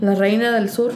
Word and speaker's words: La Reina del Sur: La Reina del Sur, is that how you say it? – La Reina del Sur, uh-huh.